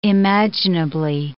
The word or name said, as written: imaginably